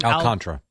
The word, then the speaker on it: Alcantara: Alcantara.